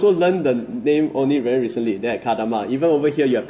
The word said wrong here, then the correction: Kendama: I thought it's spelled with K. Then why is pronounced as Ken? – K-E-N. – Oh, okay. Kendama.